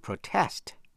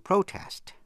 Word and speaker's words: protest: Động từ protest có hai cách đọc, hai cách phát âm. To protest, nhấn mạnh phần nhì, hay là to protest, nhấn mạnh phần nhất. Protester, P-R-O-T-E-S-T-E-R, người phản đối.